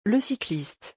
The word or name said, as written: le cycliste